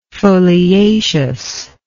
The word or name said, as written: foliaceousness